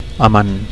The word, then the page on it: amant: Catalan